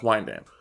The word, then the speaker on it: Wyandanch: Spelled WYANDANCH. That's how it's spelled, but it's pronounced Wine Dance.